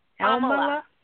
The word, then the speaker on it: Amla: Amala. – How do you spell that? – It's not going to necessarily help you because it's spelled A-M-L-A. But you could pronounce it any way you'd like.